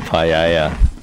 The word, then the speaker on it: paella: That's the paella. Yum. – Is that how you say it? – Yeah.